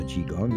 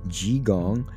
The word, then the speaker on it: qigong: You know that we talk a lot about qigong, workouts for geriatrics in our series there.